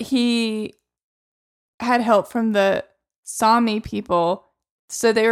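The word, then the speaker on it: Sámi: On the pronunciation guide in Wikipedia, it said that the A in Sami was pronounced like the A in bra. So there you go.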